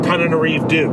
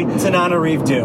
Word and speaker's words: Tananarive Due: Means Coleman, Ashley Blackwell, and Tananarive Due. No, I messed up the pronunciation. – Tananarive Due.